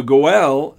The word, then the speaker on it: goel: But once again, the goel, that's how it's spelled in Hebrew, G-O-E-L, or how it's pronounced rather in Hebrew, the goel,